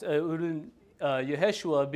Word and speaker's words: Yehoshua: The original Hebrew would have been pronounced Yehoshua, being Yeh, being from Yahweh, meaning God.